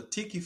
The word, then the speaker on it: Tiki: Well, the Tiki Factor is my name, right? And so my name is spelled T-I-K-I.